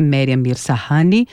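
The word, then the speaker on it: Maryam Mirzakhani: Marian Mirzahani, primeira mulher a ganhar, então, o Nobel da Matemática.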